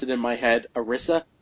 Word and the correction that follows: Arisia: Like, then I realized that there was an I in there, so when you started pronouncing it, Arisia, I don't know, that sounds pretty good to me.